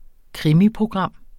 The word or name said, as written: krimiprogram